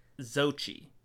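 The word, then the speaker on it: Xochitl: I have a little bit of a stutter, guys, so this name's very hard, but it's Zochi. It's a lot of letters, so it looks harder to say than it is.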